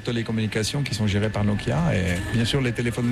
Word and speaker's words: Nokia: Mais il ne faut pas accentuer la prononciation de chaque lettre. – Non. – Nokia. – Non. On pourrait, mais c'est bon aussi Nokia.